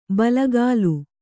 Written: ಬಲಗಾಲು

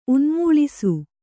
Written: ಉನ್ಮೂಳಿಸು

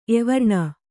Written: ಎವರ್ಣ

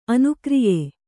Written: ಅನುಕಾರ್ಯ

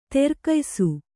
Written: ದೀರ್ಘಾಯುಸ್ಸು

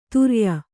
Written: ತುರ್ಯ

